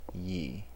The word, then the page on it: ye: American English